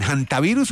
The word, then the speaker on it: hantavirus: Pues antavirus, se supone que es, pero hay mucha gente que le dice también hantavirus, ¿no? Entonces sí. Yo pensaría que es antavirus porque pues el nombre está en español. Y como todo el mundo sabe, en español la H, pues no se pronuncia, ¿no?